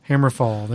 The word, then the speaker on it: Hammerfall: Hammerfall.